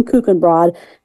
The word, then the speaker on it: Kuchenbrod: So, and again, you can reach Kim on LinkedIn, Kim Kuchenbrod.